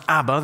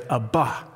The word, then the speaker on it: Abba: Not pronounced Abba, that's a very Western syllable pronounced emphasis on the first syllable, Abba, but they would say Abba, Abba.